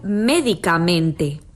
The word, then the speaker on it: médicamente: Quizá les resulte más claro observando el contraste que se produce entre el nombre medicamento y el adverbio médicamente.